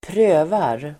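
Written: prövar

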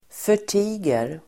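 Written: förtiger

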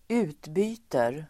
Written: utbyter

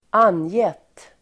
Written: angett

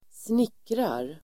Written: snickrar

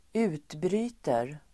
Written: utbryter

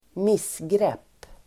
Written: missgrepp

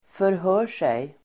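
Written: förhör sig

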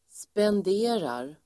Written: spenderar

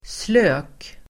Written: slök

